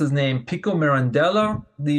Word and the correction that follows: Giovanni Pico della Mirandola: Yeah, Giovanni Pico della Mirandola.